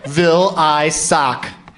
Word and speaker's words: Vilaysack: Vilaysack.